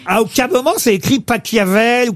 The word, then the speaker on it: Pachelbel: S'il est allemand, ça se dit Pachelbel.